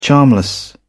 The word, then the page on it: charmless: British English